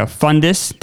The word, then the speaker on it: Fundis: Okay, I am here with Ben and Becca Fundis.